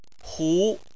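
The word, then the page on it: hu: tone 2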